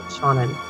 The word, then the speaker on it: Seanan: Yes, it's Mira and Seanan.